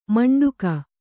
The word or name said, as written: ಮಂಡಗೆ